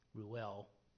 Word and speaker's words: Reuel: I keep forgetting how to pronounce it properly. Reuel. There we go.